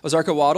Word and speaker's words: Ozarka: Is that how you say it? Ozarka? Yeah. Ozarka water, and I got back to my adventure.